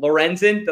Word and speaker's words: Lorenzen: Lorenzen, did I say it right? – Yes. – Yes.